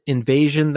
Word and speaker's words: INVSN: Well, he's in another band, Invasion, that he talked about. It's spelled I-N-V-S-N, Invasion.